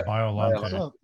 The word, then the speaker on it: Biollante: Biollante? – Yeah, Biollante.